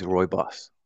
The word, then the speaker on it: Rooibos: Is it Rubus? I thought it was always Rooibos. – So I'm sure I say it incorrectly.